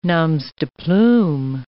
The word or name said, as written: noms de plume